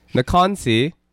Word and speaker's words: Nakansi: Nakansi. That's how it's supposed to be sounds like.